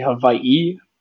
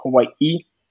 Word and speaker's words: Hawaii: So, and you could say Hawaii or Hawaii.